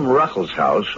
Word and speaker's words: Ruckelshaus: Here with me today in the studio is the Honorable William Ruckelshaus, who is administrator of the EPA, the Environmental Protection Agency.